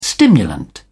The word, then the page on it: stimulant: British English